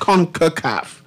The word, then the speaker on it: CONCACAF: CONCACAF. That's what you call it? CONCACAF? – Right. CONCACAF. – What CONCACAF stands for, by the way, so I can ask my next question. CONCACAF? – So basically, CONCACAF is the acronym represents, you know, the Caribbean as well as Central America and the United States and Canada.